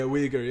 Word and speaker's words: Uyghur: Yeah, is that how it's pronounced? Uyghur? – Yeah, Uyghur, yeah.